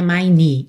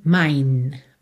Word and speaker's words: mine: Percebe que a gente não pronuncia a letra E, tá? Não é mine, e sim mine. Mine, para o som na letra N.